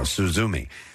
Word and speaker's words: Suzume: The Pope's Exorcist was seventh, followed by Renfield, Bo is Afraid, and Suzume. I guess is how you say that. – Suzume. – No, Suzume.